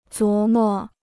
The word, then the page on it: 琢磨: zhuó mó